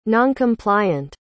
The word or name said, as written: noncompliant